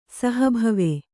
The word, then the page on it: ಸಹಭವ: saha bhava